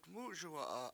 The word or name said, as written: MUSuwa7a